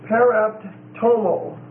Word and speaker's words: paraptoma: The next word is paraptomo. Paraptomo. P-A-R-A-P-T-O-M-A. And it's a long O.